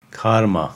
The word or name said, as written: Karma